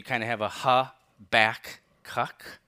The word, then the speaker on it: Habakkuk: I wanted to have a namesake for Habakkuk.